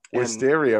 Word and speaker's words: Wisteria: And, Wisteria rhymes with listeria, and you don't want that.